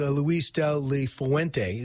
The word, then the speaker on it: Luis de la Fuente: He said as soon as he was done playing, he went to Spain to a coaching academy, and his coach was Luis de la Fuente. Is that how you say it? – Yep.